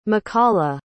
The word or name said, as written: makala